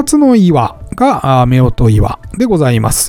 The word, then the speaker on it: 夫婦岩: こちらは男女の岩と書いて、夫婦岩と読みます。